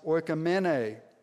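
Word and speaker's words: oikomene (οἰκουμένη): Now, a second word that is used is less familiar, and it's pronounced oikomene.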